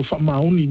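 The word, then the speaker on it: Fa'amaoni: So so Whamaoni means so you have to you do that with the right.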